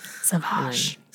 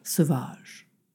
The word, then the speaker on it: Sauvage: It's like Le Planet Sauvage or Savage or whatever. How do you say savage in French? – Sauvage. – Sauvage.